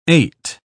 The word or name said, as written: ait